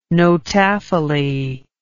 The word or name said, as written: notaphily